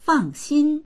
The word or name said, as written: fang4xin1